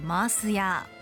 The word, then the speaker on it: 塩屋: 塩屋と書いてマース屋。沖縄の言葉で塩をマース、屋をヤーと呼ぶことからそう名付けられた塩の専門店。